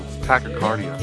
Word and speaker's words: Tachycardia: You're adding extra syllables. Tachycardia.